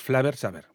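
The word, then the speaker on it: Flavr Savr: Porque tiene, es el Flavor Saber, es decir, lo último es VR, tanto en flavor como en sabor, es V y R. Entonces, Flavor Saber, bueno, se dice así, y es de 1994.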